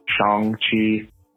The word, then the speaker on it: Shang-Chi: One, I heard that Shang-Chi is actually pronounced Shang-Chi.